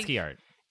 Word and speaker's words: ASCII: Like ASCII art.